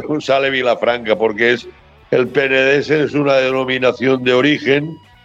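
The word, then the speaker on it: Penedès: Eh, entonces me mencionaba que el nombre de Rugby era eh, Club Villafranca del, del Penedés, creo que se pronuncia. Sí, exactamente, sí señor, sí.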